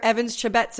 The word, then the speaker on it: Chebet: But by the time they're getting to us, we have time to say, okay, we're pretty sure Evans Chebet's about to win.